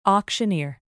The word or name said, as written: auctioneer